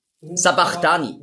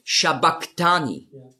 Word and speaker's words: Sabachthani: Sabachthani lesen viele. Aber das heißt eigentlich auf Aramäisch ausgesprochen ist es Shabachthani. Und das ist das CH, das Chi, ist im Griechischen als K ausgesprochen worden. Aber das S steht eben für den Sch-Laut.